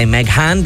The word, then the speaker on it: Meghan: I say Meghan because it's spelled M-E-G-H-A-N.